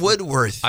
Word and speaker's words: Woodworth: I'm here with Jeff Woodruff. Did I say that right? – Woodworth. Close enough. – Woodworth. – I've been called worse at the grocery store. Don't you worry. – Exactly. A Woodworth is, you know, like Woolworths, like the counter.